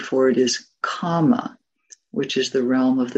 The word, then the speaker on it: kama: This one has a long A, kama.